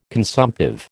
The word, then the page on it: consumptive: American English